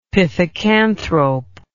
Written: pithecanthrope

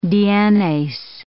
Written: DNase

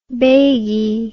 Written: bagie

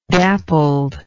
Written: dappled

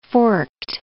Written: forked

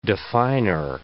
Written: definer

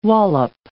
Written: wallop

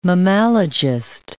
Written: mammalogist

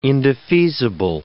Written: indefeasible

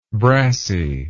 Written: brassie

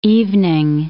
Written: evening

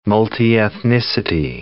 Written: multiethnicity

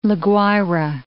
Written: La Guaira

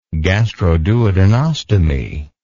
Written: gastroduodenostomy

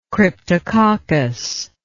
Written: cryptococcus

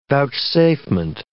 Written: vouchsafement

